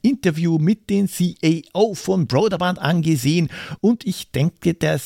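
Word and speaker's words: Brøderbund: Karateka ist ein Martial Arts Kampfspiel aus dem Jahr 1984, gepublished von Broderband. Ja, das ist die korrekte Aussprache. Broderband.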